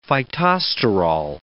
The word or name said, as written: phytosterol